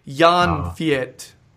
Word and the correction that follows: Jan-Fiete: Arp. All right? Jan-Fiete.